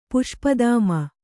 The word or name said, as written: ಪುಷ್ಪದಾಮ